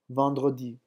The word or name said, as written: vendredi